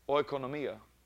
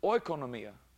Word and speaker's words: oikonomia: Nomia, N-O-M-I-A, oikonomia. And even Strong's tells to pronounce it that way. But I found out that the O-I, like our word oil, is not pronounced that way. Actually, the Greeks don't pronounce it that way. Strong's does.